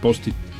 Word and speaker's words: Post-it: P-O, ajá, acento, ajá, S-I-T. – Post-it. – ¿Así? – Así, como suena. – Pues ahí suena, sonaría POSIT. – Sí, o POSIT. – No, POSIT.